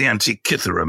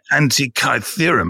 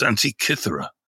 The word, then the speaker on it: Antikythera: Firstly, let me say, it's the Antikythera mechanism. It's not the Antikythera mechanism. That's how they pronounce it in the film, you know. They spent $295 million making it, and they couldn't bother to ask a Greek person how to pronounce it. It's pronounced Antikythera.